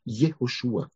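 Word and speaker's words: Jehoshua (יהושע): Und sein Name, Jehoshua, wie gesagt, wurde auf Hebräisch ausgesprochen mit Jehoshua.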